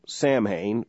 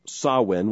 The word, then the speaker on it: Samhain: If you see that spelled, it looks like Samhain, but it's pronounced Samhain, which is the ancient pagan ceremony upon which Halloween is based.